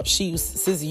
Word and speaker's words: SZA: I think it's SZA. It's S-Z-A. SZA. See, count this as my age.